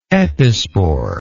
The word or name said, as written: epispore